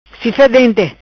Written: Cifa Dente

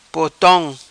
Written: Potom

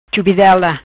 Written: Chubidela

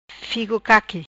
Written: Figo Caqui

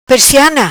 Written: Presiana

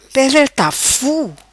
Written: Pedra Tafu

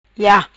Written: Ia

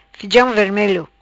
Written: Fijam Vermelo

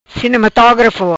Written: Cinematografo

